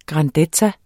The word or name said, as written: grandezza